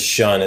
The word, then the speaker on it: Ssion: The name of the project is Shun. It's spelled kind of crazy. S-S-I-O-N.